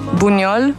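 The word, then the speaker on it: Buñol: Ehm, vorbind de uh, sărbători și alte chestii, voiam să spun că în fiecare an, în ultima miercuri din august, în Buniol, mi-a, am pronunțat bine? – da, da, da, bine.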